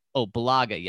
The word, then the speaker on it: Bulaga: You have Brian Beluga out there Belaga, yes, thank you.